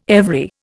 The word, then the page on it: every: Indian